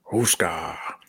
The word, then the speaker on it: Houska: And of course, the reason we're all here today is the Huska Castle, which is... – Are you going to pronounce it right once? Are you just going to say it properly one time? – Huska.